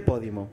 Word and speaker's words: Podimo: Pues se llama, ¿dice Podimo o Podimo? – Se dice Podimo.